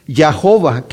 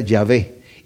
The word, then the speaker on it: Yahvé: En inglés tenemos Yahova, que realmente tomaron las vocales de Adonai, para se las metieron entre la YHBH para que sonara Yahova, pero se cree que esa no es la pronunciación, lo más correcto que sabemos que puede ser Yahvé.